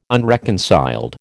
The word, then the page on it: unreconciled: American English